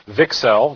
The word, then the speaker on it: VCSEL: which is pronounced VCSEL. It's important to notice that the emphasis is on VIC. The emphasis is not on cell, despite the fact that we've been accused of that in the past.